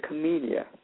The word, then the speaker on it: Camellia: But Banjo had said it was a Camilla flower. – And is it a Camilla or a Camellia? I don't know if I say that wrong. Is it a Camilla? – The flower is a Camellia.